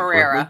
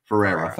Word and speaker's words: Ferreira: Marcello Ferreira, I believe. I hope I'm saying that Ferreira. – Correctly. Ferreira. Thank you.